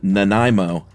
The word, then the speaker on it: Nanaimo: Had the most hilarious, unique pronunciation of, I think it's the Nanaimo cookie is correct, right? – Yeah. – What did you say? – Nanaimo.